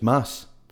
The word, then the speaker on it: Mads: You know that's not how you pronounce his name? – Yeah, it's probably... – It's Mass. – Ah, well, whatever. – The D is silent. It's Mass.